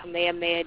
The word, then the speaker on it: Kamehameha: No, you know, there's a road sign here that's spelled just like that, like Ka-me. Ha, me, ha. You know what that means here? – You're in Hawaii, though. – Yeah, but you know what that means? It means Kamehameha. It's pronounced Kamehameha.